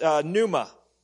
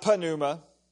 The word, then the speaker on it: pneuma: It is pneuma, not panuma. The P is silent. Pneuma.